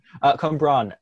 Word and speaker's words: Cwmbran: How do you say it? – Cwmbran. It should be more phlegm in your voice,